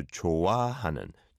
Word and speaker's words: 좋아하는: And I think the bit to improve in your sentence was 좋아하는. And then an ah sound is the second syllable. So 좋아하는, 좋아하는.